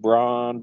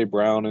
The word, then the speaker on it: Brown: It looks like it's said Braun, but, you know, they say Brown on TV.